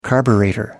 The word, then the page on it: carburettor: American English